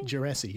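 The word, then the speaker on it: Djerassi: And then this next guy, Carl, I think I mispronounced the name. – Jurassi? Jurassi, yeah.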